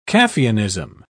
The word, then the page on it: caffeinism: British English